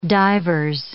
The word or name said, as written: divers